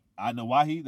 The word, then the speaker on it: Anoa'i: But like the Anoa'i family, however you pronounce it. – Anoa'i. – That's how you pronounce it? Anoa'i?